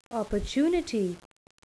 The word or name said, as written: opportunity